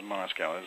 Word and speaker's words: Moskau: Yeah, if I remember correctly, the way you pronounce it is Moscow. His last name was M-O-S-K-A-U.